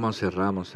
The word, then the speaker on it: Montserrat: Pues mira, yo lo siento mucho lo de Montserrat. – Sí. – Caballé. – Una pena, ¿verdad? – Montserrat. Lo he dicho malamente. – No, Montserrat, está bien.